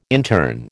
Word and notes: intern: American English

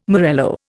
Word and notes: morello: Indian

